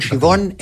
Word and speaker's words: Siobhan: Und Siobhan, S-H-E-E-V-O-N. – Ganz wichtig, ein irischer Mädchenname, der aber in Irland anders geschrieben wird, damit die Menschen hier den aussprechen können.